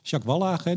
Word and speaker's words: Jacques Wallage: Jacques Wallage die leidde dat dacht ik.